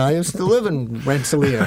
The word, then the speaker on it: Rensselaer: It shouldn't be up to us to say what they do in Rensselaer, which, by the way, is pronounced Rensselaer.